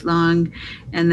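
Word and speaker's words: Lange: So we read the Pandemic by Sonia Shaw and then the Impatient Dr. Long. I'm sure I'm saying his name wrong. Joep Long. And then... – Longa, yeah.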